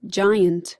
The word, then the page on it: giant: American English